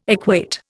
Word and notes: equate: Indian

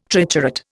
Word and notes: triturate: Indian

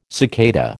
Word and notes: cicada: American English

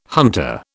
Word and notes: hunter: British English

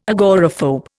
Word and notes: agoraphobe: Indian

